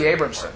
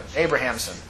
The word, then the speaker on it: Abrahamson: Shirley Abramson. Abrahamson.